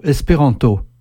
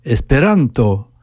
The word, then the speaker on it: esperanto: Si je dis en français le mot « espéranto », chaque syllabe est prononcée à peu près avec la même intensité. Mais si je dis « esperanto », j'accentue l'avant-dernière syllabe.